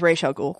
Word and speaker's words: Ra's al Ghul: Not just between us, but it is Ra's al Ghul.